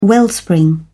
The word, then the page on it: wellspring: British English